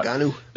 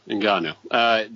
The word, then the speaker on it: Ngannou: I'll never pronounce his last name right, but you know who I'm talking about. – Ngannou. – Thank you, Ngannou.